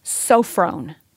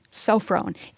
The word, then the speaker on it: sophron: The word here that's translated self-control in the English Standard Version is the Greek word sophron. Sophron. It's spelled S-O-P-H-R-O-N.